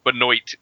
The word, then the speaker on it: Benoit: That's the funny thing is his name is actually pronounced Benoit. – Benoit. – They Americanized that name.